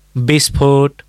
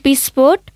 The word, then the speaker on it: विस्फोट: वैमनस्य, विस्फोट, विष, व्याधा, यी शब्दमा लेख्दा व लेख्नुपर्छ, बोल्दा चाहिँ ब उच्चारण हुन्छ, होइन त काका? – हो, खास कुरा यही हो।